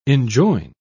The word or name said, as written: enjoin